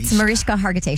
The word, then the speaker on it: Mariska Hargitay: I always feel like I'm not pronouncing her name right. Am I? – It's Mariska Hargitay. She said it last night.